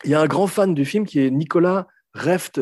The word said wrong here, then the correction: Refn: euh, Reft, Refn, j'arrive jamais à prononcer nom. Voilà, bravo.